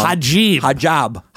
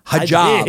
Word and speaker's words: hijab: Hijab. – Hijab.